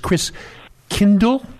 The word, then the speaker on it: Christkindl: And in German, the pronunciation of the Christ child is Christkindl. And from that, we get Kris Kringle.